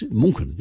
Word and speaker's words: München: When they say, oh, I want to pronounce it like the locals, and I say, well, how are you going to pronounce Munich in future? – München, isn't it? – It's München. Yes, it's München in Germany.